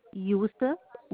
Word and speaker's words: Yossef: Yosef, Y O S S E F like Frank.